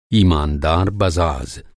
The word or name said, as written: बज़ाज़